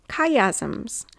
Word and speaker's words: chiasm: Chiasms. And this is sometimes called chiasmus, but chiasm or chiastic structure. And it's spelled C-H-I, chiasm.